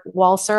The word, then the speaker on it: Walser: And then another one about the Swiss writer, Robert Walser. I think his last name is pronounced Walser, W-A-L-S-E-R.